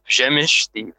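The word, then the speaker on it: Przemyśl: The Great Siege of Przemysl. And I mispronounced that right off the start, folks. It's Przemysl. The L is somehow silent, but maybe we'll solve that mystery in the course of the podcast.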